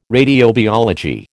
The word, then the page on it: radiobiology: American English